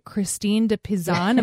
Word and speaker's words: Christine de Pizan: It's by Christine de Pizan. Am I saying that right? – You are right.